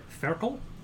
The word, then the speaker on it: Ferkel: I'm going to be playing Ferkel. Which is the German word for a piglet. – Okay, spell it. – F-E-R-K-E-L.